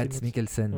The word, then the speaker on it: Mads Mikkelsen: Mads Mikkelsen, ouais.